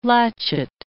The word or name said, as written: latchet